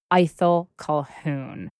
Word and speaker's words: Ithell Colquhoun: And that is the painter and writer and many other things, Ithell Colquhoun. And let's just clarify that is indeed how you think we should pronounce her name, correct? – It is how we pronounce her name.